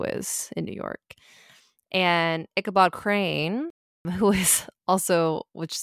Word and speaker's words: Ichabod: So basically we have, it's Ichabod, right? That's how you say it? – Mm-hmm. Ichabod Crane.